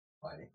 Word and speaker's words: Pliny: Pliny. That's how the brewery that makes the beer called Pliny the Elder pronounces it.